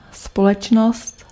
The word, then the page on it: společnost: [spɔlɛtʃnɔst]